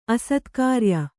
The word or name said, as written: ಅಸತ್ಕಾರ್ಯ